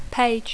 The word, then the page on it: page: English